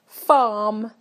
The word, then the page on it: farm: British English